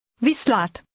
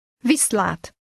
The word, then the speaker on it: Viszlát: Viszlát. Viszlát. – How does she say bye? – Viszlát.